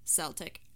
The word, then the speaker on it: Celtic: People who study the Celtic culture, language, and history usually pronounce it as a Celtic, but sports fans say Celtic.